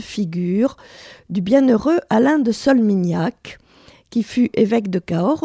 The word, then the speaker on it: Solminihac: Alors, Solmignac, ça s'écrit S-O-L-M-I-N-I-H-A-C. Voilà.